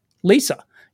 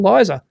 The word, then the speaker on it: Lisa: I just didn't, I don't know, but back in the day, it occurred to me that it was his daughter's name, like Lisa, Apple Lisa. I always called it, Apple Liza.